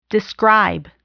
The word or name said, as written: describe